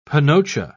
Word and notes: panocha: British English

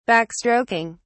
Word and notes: backstroking: British English

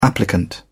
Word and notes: applicant: British English

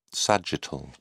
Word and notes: sagittal: British English

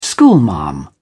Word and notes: schoolmarm: British English

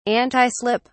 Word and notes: antislip: British English

